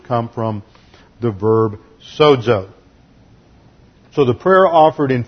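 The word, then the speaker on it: sozo (σώζω): In the Greek, S-O-Z-O, long O, sozo.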